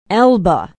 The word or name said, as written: elba